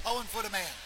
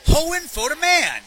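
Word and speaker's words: Hoinfodaman: How do you pronounce the name of that Primus song? – Hohenfodeman. – Hohenfodeman. Ah, so, okay. So it's like how Dire Maker's Jamaica, kind of?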